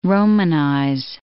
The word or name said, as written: romanize